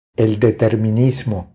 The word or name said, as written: El determinismo